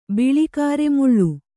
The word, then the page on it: ಬಿಳಿಕಾರೆಮುಳ್ಳು: kannada